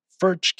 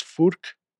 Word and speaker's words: Furch: That is, well, here in the States, we would pronounce it as Furch Guitars, F-U-R-C-H, Guitars. But they pronounce it as Furk, and I'm sure the rest of the world pronounces it as Furt Guitars as well.